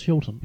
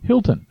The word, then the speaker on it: Hylton: How would you say that? H-Y-L-T-O-N. – I think it's Hilton. – Hilton.